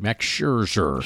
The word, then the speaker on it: Scherzer: Max Scherzer.